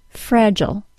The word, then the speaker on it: fragile: Today we're going to talk about a pattern that explains American and British pronunciation of multisyllable words that end in I-L-E. The word F-R-A-G-I-L-E is pronounced fragile, ending in schwa plus L sound in the U.S., and fragile, ending in long I plus L sound in the U.K.